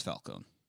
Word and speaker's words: Falcone: Yeah, well, when I was in the old country, that's how we pronounce it. – When they go for a little bit of the gabagool, maybe they put that emphasis on there. But here at the courthouse, he's just Falcone.